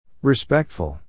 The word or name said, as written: respectful